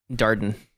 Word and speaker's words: Darden: Darden. – Darden. – It's like garden, but you spell it with a D in the front instead of a G.